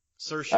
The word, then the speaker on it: Saoirse: Saoirse. Inertia. Saoirse.